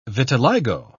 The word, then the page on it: vitiligo: British English